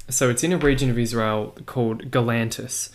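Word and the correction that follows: Gaulanitis: or Galanitis is the correct pronunciation.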